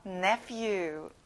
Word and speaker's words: nephew: 가운데 ph를 f 발음이랑 똑같이 하는 거예요. nephew.